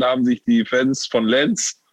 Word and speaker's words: Lens: Nee, Lille, nicht Nantes. Also, L-E-N-S, Lenz.